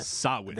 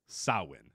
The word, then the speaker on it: Samhain: Did you learn how to pronounce the name of this pay per view yet? Um, no, I did not. Sawin. They... Sawin is how you pronounce it. Salwin. Yes, even though there's an M and an H, several letters.